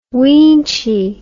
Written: weenchy

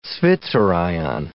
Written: zwitterion

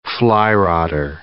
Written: flyrodder

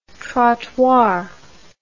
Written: trottoir

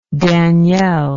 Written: danielle

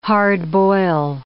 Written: hard-boil

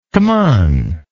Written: cmon

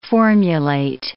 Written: formulate

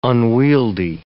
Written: unwieldy

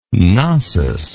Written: Cnossian

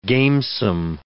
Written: gamesome

